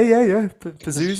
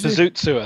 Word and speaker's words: Pazuzu: Is it actually called Pazuzu? – Yeah. Pazuzu. – Pazuzu, I think it's pronounced.